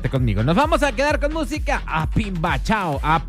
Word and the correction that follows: Pambichao: siempre lo he dicho mal, me acabo de dar cuenta. A Pambichao, nos dice Manuel Turizo.